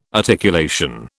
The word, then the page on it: articulation: British English